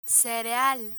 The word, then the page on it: cereal: spanish